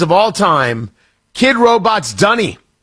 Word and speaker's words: Dunny: D-U-N-N-Y. – A Dunny? – I do not know what this is.